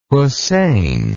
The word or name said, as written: hosein